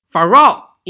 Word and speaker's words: Pharaoh: 你看发音都很相似，明显法老就是音译过来的。 Pharaoh 英语怎么拼呢？P H A R A O H，P H A R A O H，Pharaoh 就是法老的意思。